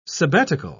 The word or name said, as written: sabbatical